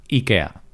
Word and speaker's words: Ikea: Ikea, Ikea, que pronuncian los anglosajones, quizá, y no sé cómo lo pronuncian los suecos, pero bueno, eso, Ikea. En España, esto es un podcast en español, así que nosotros enseñamos la pronunciación española, Ikea.